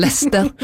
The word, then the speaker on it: Leicester: Kjekt å høre på, men det heter Leicester.